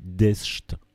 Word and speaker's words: deszcz: Eh bien, ça se prononce, je sais pas, mais ça se dit deszcz.